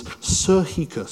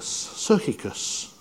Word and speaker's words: psychikos: But the word soulishness here, or that word is psychikos, psychikos. I got the pronunciation written down, psychikos.